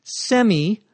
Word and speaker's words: semi: The prefix is S-E-M-I. It can be pronounced either semi or semi. The last vowel there can be pronounced either as an I or an E.